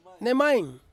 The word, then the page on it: nemain: Ansus